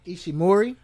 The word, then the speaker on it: Ishimori: Ishimori? – Yeah. – Yeah, you get 5 points for being able to pronounce that.